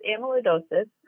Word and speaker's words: amyloidosis: Now, I mentioned this condition, amyloidosis. What exactly is it, and am I pronouncing it correctly? – You want to take that? – Sure. Yep. So you are. So it's amyloidosis.